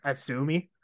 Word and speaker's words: AZM: See, what I can't really talk is when I first saw it's supposed to be pronounced Asumi. – Okay. – But it does it. So it is. It does look like ACM, but technically it's Asumi.